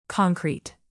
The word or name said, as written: concrete